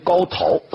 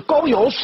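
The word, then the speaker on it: 皋陶: 上古有个帝王叫高尧啊，这个字很多人念成高陶是错的，陶渊明的陶啊，但是在这里念尧，这个字是高尧氏的后裔，都是子爵的小诸侯国。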